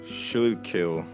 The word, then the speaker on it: Schuylkill: S-C-H-U-Y-L-K-I-L. How would you pronounce that word? S-C-H-U-Y-L-K-I-L-L. – Schuylkill. – Not bad. That is the Schuylkill.